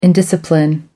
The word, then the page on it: indiscipline: American English